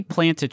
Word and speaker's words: planta: 20 plant a tree, yeah. Are we saying that planta rhymes with 20? – Planta. 20. 20. 23.